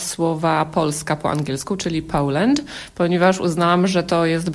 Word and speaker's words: Poland: To właśnie w związku z tym, że my wymawiamy to słowo niepoprawnie, także jest podobne do Holandii. No i pokazałam właśnie tę poprawną wymowę, czyli Poland przez O. – Distinguished guests, people of Poland. Poland.